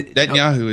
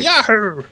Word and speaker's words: Netanyahu: Does Netanyahu know that it sounds like I'm pronouncing that wrong. – No, Netanyahu is his name. – Okay, sorry. – Netanyahu!